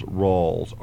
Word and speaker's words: Rawls: my name is Charles Rawls, R-A-W-L-S.